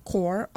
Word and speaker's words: Corps: It's CA Climate Action Core, all one word, and core, CORPS. – Yeah. But it said core, okay? – Mm-hmm. Yeah. – But it's spelled PS.